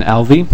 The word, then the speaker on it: Alvey: My name is Jason Alvey. Last name is A-L-V-E-Y.